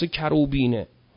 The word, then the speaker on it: کروبین: کروبینه، کروبین گفتیم تشدید نداره، کروبین غلطه. کروبین نام فرشته ایست، مثل کروبیل تلفظ میشه، کروبین هم گفته میشه که از فرشتگان مقربه.